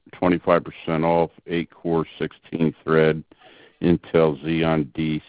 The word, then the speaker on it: Xeon: Okay, the value server is now 52% off 4-core/8-thread Intel Xeon D CPU. How do you pronounce that? – X-E-O. – Xeon? – Yeah, Xeon.